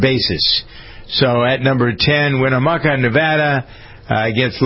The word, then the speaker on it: Winnemucca: Winnemucca. – Is that it? – Yeah, Winnemucca? Yeah, Winnemucca, Nevada, with just a little under 8 inches average rainfall.